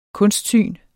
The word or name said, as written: kunstsyn